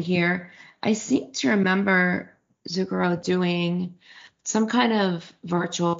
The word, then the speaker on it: Zucchero: Bono's gonna be on Zucchero. Is that how you pronounce his name? – Yes.